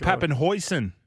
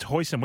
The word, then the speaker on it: Papenhuyzen: It's actually pronounced Papenhuyzen. – Oh, I knew I'd It is to be official. – I thought it was Papenhuyzen. – Nah, it's Hoysen. We actually interviewed him and asked him.